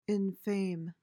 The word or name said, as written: infame